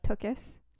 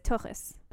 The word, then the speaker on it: tuchus: like, the real way to pronounce it is tuchus. – Sure. – Yeah. That's you got to get the ch in there.